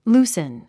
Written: loosen

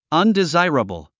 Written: undesirable

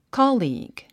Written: colleague

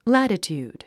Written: latitude

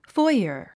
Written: foyer

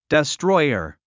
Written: destroyer

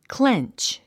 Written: clench

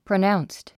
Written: pronounced

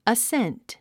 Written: ascent

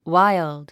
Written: wild

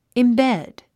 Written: embed

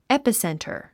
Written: epicenter

